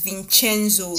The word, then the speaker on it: Vincenzo: Yes, that's the American pronunciation. You know he's American. – Oh my God. But Vincenzo at that point told him, "You are actually the first person that is pronouncing my name very well." – Yes, that is like the Korean American pronunciation. You know, Koreans, they tend to speak American English whenever they learn English, but the right pronunciation is Vincenzo. Is an Italian name.